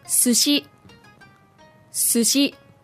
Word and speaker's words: sushi: In English, we say sushi. But in Japanese, it should be... – Sushi. Sushi. – Sushi. So the stress on the two syllables is exactly the same, as opposed to in English where we put the stress on the first syllable.